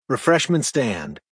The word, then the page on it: refreshment stand: American English